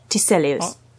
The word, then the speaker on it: Tiselius: His name is Arne Tiselius. Am I saying it right? – Arne Tiselius. – Yes.